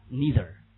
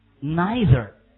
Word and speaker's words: neither: 我们说美国人叫 neither，老鹰叫 neither，看到没有？所以你怎么读行啊？美国人简单一点叫 neither，那么美国人、英国人叫 neither，那么那个词呢，肯定是怎么说？either 一样的读法，懂吗？